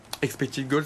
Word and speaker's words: expected goal: Expected goal, sa prononciation est bonne. – Très bonne.